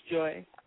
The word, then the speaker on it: Joi: Yes, it's Joy. – Yeah. – Okay, perfect. Because I know it's spelled a little differently, but mm-hmm.